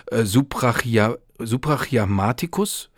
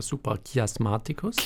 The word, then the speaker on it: suprachiasmaticus: Wenn ich das richtig mir angelesen habe, ist das der Nucleus suprachiasmaticus, falsch ausgesprochen. – Ja, suprachiasmaticus.